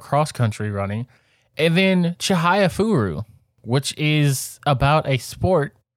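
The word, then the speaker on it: Chihayafuru: And Chihaya Furu, and I am pronouncing that right.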